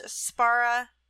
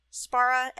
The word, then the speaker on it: Szpara: One of my favorite writers is a author named KM, I believe it's pronounced Spara. Spara, S-Z-P-A-R-A.